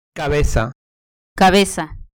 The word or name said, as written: cabeza